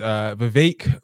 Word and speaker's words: Vivek: Speaking of running for president, Vivek Ramaswamy, Vivek rhymes with cake, got a wake-up call when he invited the most racist woman in America, Ann Coulter, onto his podcast.